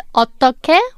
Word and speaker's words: 어떻게: The first one is 어떻게, which means how. The final consonant of the second syllable is 히읗, 어떻, and the last syllable is 게, 어떻게, but it's pronounced as 어떻게. Because when 히읗 meets 기억, it's combined and pronounced as 기억, so 어떻게.